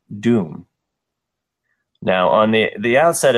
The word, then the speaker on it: Dume: Uh, so I, I, had to put on the closed captions just to, to just to check, and it's it's Doom, but it's spelled D U M E, which is huh.